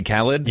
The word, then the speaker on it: Khaled: And you pronounced one of them wrong. – Khalid? – Yeah. – Thank you. – Is it Khalid and DJ Khaled? – Yes.